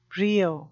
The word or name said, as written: brio